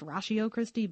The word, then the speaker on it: Ratio Christi: And it's Latin, so it's pronounced Ratio Christi, but there you have it.